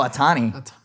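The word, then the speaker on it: Ohtani: A Tony. Oh, Otani.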